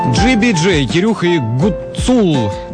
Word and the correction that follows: Гуцул: Да что ж я ударение не могу? Гуцул, вот правильное ударение.